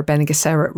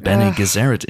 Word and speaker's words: Bene Gesserit: Well, Helen, I have to, of course, you know, get my Dune-splaining hat onto you and correct you in your pronunciation of Bene Gesserit. It's Bene Gesserit, as we all know.